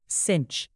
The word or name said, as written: cinch